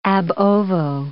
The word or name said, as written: ab ovo